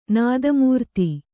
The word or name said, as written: ನಾದಮೂರ್ತಿ